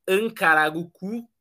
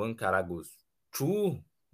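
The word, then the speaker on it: Ankaragücü: Ontem tomou 3 a 0 do glorioso Ancaragucu, ou Ancaragucu, né? Aquela, aquele C ali às vezes é pronunciado de maneira diferente.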